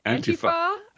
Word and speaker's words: Antifa: Antifa.